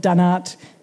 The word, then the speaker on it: dunnart: Ebony's PhD looks at developing assisted reproductive technologies like in vitro fertilisation for a very small and very cute Australian marsupial, the fat-tailed dunnart. Is that how you pronounce? Spot on.